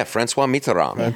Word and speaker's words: François Mitterrand: Yeah, François Mitterrand.